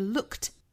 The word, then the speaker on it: looked: 那好，我们来看下面 looked forward to，这里面有个 look 加上了 ed，我们注意这里面不要读成 looked，而是 looked，这里属于是清清浊浊的语音规则。那尾部的这个 ed 读成 t，